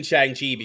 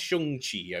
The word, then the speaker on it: Shang-Chi: So you'll report back next week with the Shang-Chi, or I believe it's pronounced Shang-Chi, I believe is how it's pronounced. – Shang-Chi.